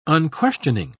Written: unquestioning

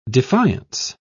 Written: defiance

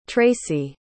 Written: tracy

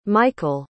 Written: michial

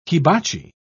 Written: hibachi

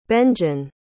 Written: benjen

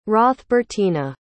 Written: hrothbertina

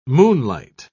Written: moonlight

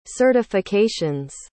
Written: certifications

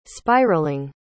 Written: spiraling

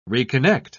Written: reconnect

